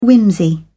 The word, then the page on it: whimsy: British English